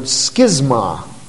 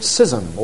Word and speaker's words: schism: It's the Greek word schisma, from which we get our English word schism or schism. Both pronunciations are acceptable.